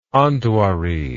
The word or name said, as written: Andvari